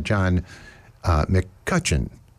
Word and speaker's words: John McCutcheon: I was talking about upcoming shows at the Freight, and I mispronounced one of the artists. It is John McCutcheon, and not something that I said.